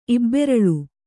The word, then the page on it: ಇಬ್ಬೆರಳ್: ibberaḷ